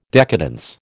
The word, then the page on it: decadence: American English